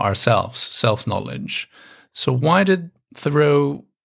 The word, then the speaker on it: Thoreau: But one of the philosophers, or a philosopher of sorts that you talk about in your book, Henry Thoreau, is that how you pronounce it? Henry Thoreau? – I tend to say Thoreau, but I've heard Americans pronouncing it the way that you are, so that might also be correct.